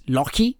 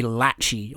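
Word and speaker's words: Lachie: his nickname is Lockie, is, you know, Lockie, like Lucky, so it's kind of cute. But if you didn't realise that it's Lock, like in a Scottish pronunciation, then you just think his name would be Latchy or something.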